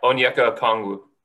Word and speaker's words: Okongwu: how do you say his name? Okongwu? – Yeah, Onyeka Okongwu.